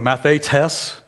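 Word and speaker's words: mathetes (μαθητής): In the Greek, it's mathetes. I always get the emphasis on the wrong syllable. It's on the third one, mathetes.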